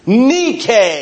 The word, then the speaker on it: Nike: Nike. Nike is how they would have pronounced it back then.